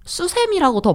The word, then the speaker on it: 수세미: 저도 사실 수세미라고 더 많이 해요. – 수세미 지금도. – The official correct pronunciation of 수세미 is 수세미. – But, 수세미. – Right, but people often call it as 수세미.